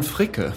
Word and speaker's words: Fricke: Florian Frick. You'd be able to pronounce that better. – Florian Fricke.